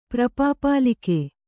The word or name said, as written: ಪ್ರಪಾಪಾಲಿಕೆ